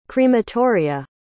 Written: crematoria

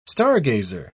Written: stargazer

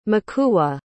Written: makula

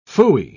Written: phooey

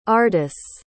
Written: ardice

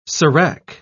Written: serac

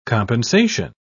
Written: compensation

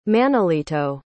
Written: manolito

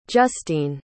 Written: justeene

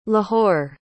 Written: Lahore

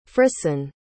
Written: frison

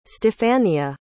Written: stephania

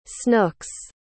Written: snooks

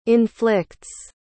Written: inflicts

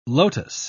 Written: lotus

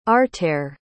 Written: artair

